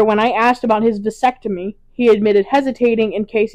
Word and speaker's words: vasectomy: This is aside from the complications women experience, unlike men who undergo vasectomies. Did I say that right? – Vasectomy.